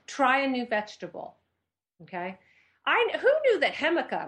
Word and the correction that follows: jicama: am I saying that right? Hemica. How do you say that? – Jicama? – Nope. Jicama. I say, loser.